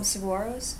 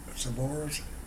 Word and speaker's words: saguaro: The Arizona people pronounce it saguaro. Saguaro. – Oh. – As if there was no G in it, but just a W. Saguaro. Uh-huh. I find that El Paso people tend to pronounce it saguaro. – Yeah. – With the G.